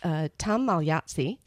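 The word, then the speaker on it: Magliozzi: phonetically because I will get it wrong. So it's Tom Malyatsi. See, I got it right the first time.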